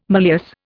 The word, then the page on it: malleus: Indian